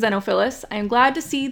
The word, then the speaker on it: Xenophilius: You mentioned it a little bit, but so whenever they are reading, whenever Hermione is reading about the Deathly Hallows, basically Xenophilus. I literally Googled that, so I pronounced that correctly. I was like, how do I pronounce his name? So it's spelled like X-E-N-O.